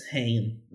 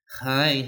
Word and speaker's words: Haim: Ham. Eu não, nunca sei falar o nome delas. Haim, Ham, é, é né? Ah, mas é difícil, é uma pronúncia complicada. Mas H-A-I-M.